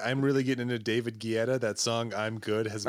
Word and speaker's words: Guetta: It's David Guetta.